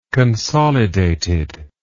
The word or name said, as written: consolidated